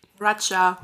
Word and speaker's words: Raya: Wie spricht man R-A-Y-A aus? Raya. – Raja. – Raja.